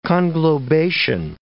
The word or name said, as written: conglobation